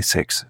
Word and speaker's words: XES: Alors ça s'écrit X-E-S, mais normalement faut le prononcer Sex d'après ce qu'ils ont dit, euh, voilà, sur leur site, sur la page Bandcamp, normalement c'est écrit ça. Puis de toute façon c'est Sex à l'envers, et donc euh, Forbidden Love, hein, on peut imaginer tout ce qu'on veut.